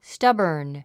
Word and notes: stubborn: stʌ́bə r n; スタボォン

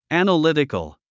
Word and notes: analytical: æ̀nəlítikəl; アナリティコゥ

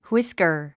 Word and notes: whisker: hwískər; フイスカァ

